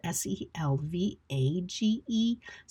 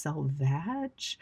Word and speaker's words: selvage: even though it's spelled S E L V A G E, so we would expect that it was actually pronounced selvage, but we don't have to say that more than the one time I just said it. Thankfully, we can say selvage like it's spelled V E G, right? Um, or EDGE, like edge.